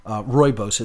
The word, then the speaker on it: rooibos: Rooibos, it's spelled R-O-O-I-B-O-S, pronounced rooibos. Looks like rooibos, but it's pronounced rooibos, and it's just, it's fantastic.